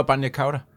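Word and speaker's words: bagna cauda: Nå, no, banja cauda.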